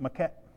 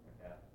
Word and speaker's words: macadam: They had, I can't say it, maquette. They had roads with blacktop. – How do you say that? – Macadam.